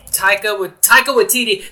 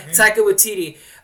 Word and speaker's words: Taika Waititi: Taika Waititi. That's before I looked it up, too. That's two for two. Yeah. Taika Waititi.